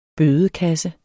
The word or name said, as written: bødekasse